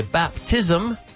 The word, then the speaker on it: baptism: You can say baptism. So remember an ism, not an ism, it's an ism with an extra vowel sound.